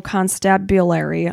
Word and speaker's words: constabulary: In 2002, Wayne joined the Kent Special Constabulary. Am I saying that right? – Constabulary, I yeah, believe. constabulary. Like constable.